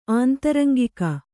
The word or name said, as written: ಆಂತರಂಗಿಕ